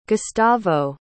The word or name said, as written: gustavo